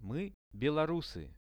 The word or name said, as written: my belorusy